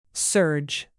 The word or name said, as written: surge